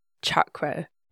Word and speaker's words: chakra: And another really important, often mispronounced example is chakra. Chakra is with a hard ch, just like you use in Chaturanga.